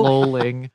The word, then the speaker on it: lolling: Does Josh Charles think the word lolling rhymes with the word rolling? Because that's how he pronounced it, even though lolling. – She says it the right way right after that.